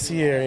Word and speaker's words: Sierra: It is Sierra, yes, Sierra. Not Sierra. – Yeah, well, I mean, the E wouldn't imply that. It's pronounced exactly the same as Pierre.